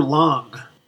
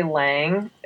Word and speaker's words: Lang: Is it Lang or Lang? – I mean, most Americans say Lang. Technically, in German, there's that long like ah sound doesn't exist. Okay. So it's fine. It's fine to say Lang.